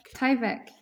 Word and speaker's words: Tyvek: And on Instagram, I've noticed too that you're producing these highly abstract pieces of embroidery out of Tyvek. Is that how it's pronounced? This industrial material, Tyvek? – Tyvek, yes.